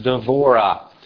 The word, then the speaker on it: Devorah: So the Hebrew way to pronounce or say Deborah is Devorah.